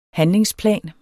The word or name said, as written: handlingsplan